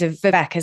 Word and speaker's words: Vivek: It's so interesting to hear you talk about, his name's Vivek, isn't it? That's how you pronounce it.